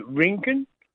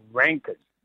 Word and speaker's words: Rinkin: My name is actually Rinken. But everyone pronounces it and writes it down as Rankin. – So it's Rinken. – Yeah, not Rankin. – How do you actually spell your surname? – R-I-N-K-I-N.